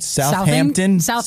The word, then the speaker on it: Southampton: It involved researchers from Imperial College in London, King's College, and the universities of Cambridge, Southampton. Am I saying that right? – Southampton.